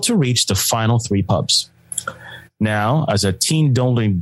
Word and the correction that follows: teetotaling: dude, oh, did I say that right? Teetotaling. – Yeah, yeah, he's a teetotaler, which means he doesn't drink anymore.